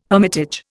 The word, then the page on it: hermitage: Indian